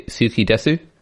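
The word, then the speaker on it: suki desu (好きです): Sushi got ski des. I think I should say suki desu. – I think that's the authentic pronunciation.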